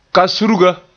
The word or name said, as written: kasurga